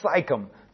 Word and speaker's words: Sychem: And it's also called Sychem. Sometimes it's spelled Sychem. S-Y-C-H-E-M. But it's the same place, Shechem.